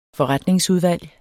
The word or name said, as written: FORRETNINGSUDVALGET